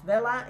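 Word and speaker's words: Wella: I think it's pronounced Vella. It's W-E-L-L-A.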